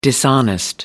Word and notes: dishonest: American English

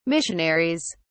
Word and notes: missionaries: British English

